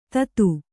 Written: ಥತ್

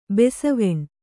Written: ಬಾಸೆವೆಣ್

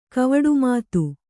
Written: ಕವಡುಮಾತು

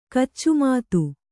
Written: ಕೈಚಮತು

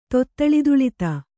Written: ತೊತ್ತಳದುಳಿತ

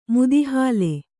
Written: ಮುದಿಹಾಲೆ

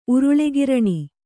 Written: ಉರುಳೆಗಿರಣಿ

